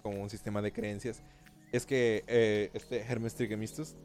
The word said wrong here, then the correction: Hermes Trismegistus: así se dice. No, no lo dije bien, pero, lo, lo, voy a decir bien. Hermes Trismegistus. Así lo había dicho bien, creo.